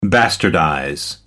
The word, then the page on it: bastardize: American English